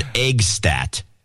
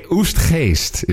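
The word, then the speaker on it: Oegstgeest: From a town I'll never pronounce in a million years, Eggstadt. – Very close. Try Oostgeest. – How is that close? – It's not, but I like the Eggstadt. That's good. – Oostgeest. – Oostgeest is how you pronounce it.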